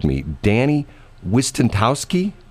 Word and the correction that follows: Wicentowski: did I get it right? – Close enough. – Okay, pretty good. – Go go ahead and pronounce it for me. – Uh, Danny Wicentowski. That's my name.